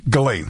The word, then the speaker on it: Ghislaine: Pronounce the first name for me. – Ghislaine? – Ghislaine. – Yeah.